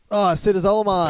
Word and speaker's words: acetazolamide: Acetazolamide.